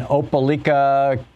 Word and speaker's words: Opelika: Natalie in Opelika, Alabama. Am I saying that right, Natalie? – Yes, you are, Tom.